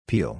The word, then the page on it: peal: long-i